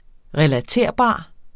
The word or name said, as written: relaterbar